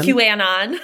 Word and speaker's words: QAnon: And I was like, wait, is that not how you say it? It wasn't. It's QAnon.